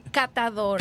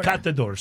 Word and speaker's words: catador: To become a cazador. What does that mean? – Catador. – Catador. Sorry, I said it wrong.